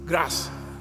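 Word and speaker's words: grâce: I believe there's a word in French that is spelled almost G R A C E. Grass.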